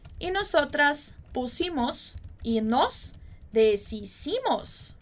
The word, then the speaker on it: deshicimos: Apesar de a gente ter esse SH ali em cima, você vai ver que a gente não vai falar deshicimos, né? Como se fosse aquele som de sh, de chiado, né? De SH que a gente pronunciaria no português. Então, muito cuidado com essa pronúncia.